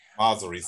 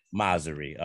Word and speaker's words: Mosseri: Adam Mosseri, and I hope I said that right, baby. – Mosseri, sir. – Mosseri, okay.